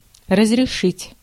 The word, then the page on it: разрешить: /rəzrʲɪˈʂɨtʲ/